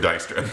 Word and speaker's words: Dijkstra: Apparently, she's also there as a representative of a person named Dijkstra, who is the... – He's Dijkstra. – Dijkstra? – If it's like the Dutch pronunciation, it's Dijkstra. – Oh, maybe it's Dijkstra, yeah.